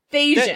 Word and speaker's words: Thasians: Because I said Thalesians, which is not. – And Thalesians, it. Thasians. – That's it. – The Thasians. Oh, it's definitely more syllables. – Okay, so there's no L, the Thasians. – There's no, it's just Thasians.